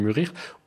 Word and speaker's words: mûrir: Vous avez utilisé le mot mourir au lieu de mûrir, ou vous l'avez prononcé mourir au lieu de mûrir.